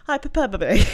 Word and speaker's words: hyperbole: What's a hyperbole? – Hyperbole. – Yeah. – You put one too many syllables. Hyperbole.